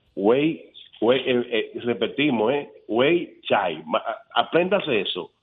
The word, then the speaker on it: Weichai: se escribe rarísimo pero se pronuncia Weichai